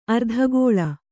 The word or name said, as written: ಅರ್ಧಗೋಲ